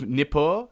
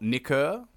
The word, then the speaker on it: Nippur: South of Nippur, not Nikur, the really hard city to pronounce that I fucked up last episode.